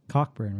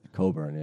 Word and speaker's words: Cockburn: Well, it's Cockburn, right? – Coburn, yeah. – Coburn. – Many people pronounce it Cockburn, which is sounds painful.